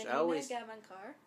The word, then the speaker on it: Janina Gavankar: Aiden is wonderfully portrayed in the Battlefront 2 campaign by Janine. Oh my gosh, Janina Gavankar.